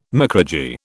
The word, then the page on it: micrurgy: British English